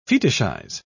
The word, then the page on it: fetishize: British English